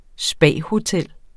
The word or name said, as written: spahotel